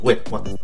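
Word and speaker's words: Waterston: E questo casino di non poco conto vede il protagonista Sam Water, eh, Waterstone, Waterstone, sì, Waterstone, pronuncia più corretta,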